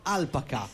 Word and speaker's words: Alpaca: L'accento è sulla prima A, Alpaca.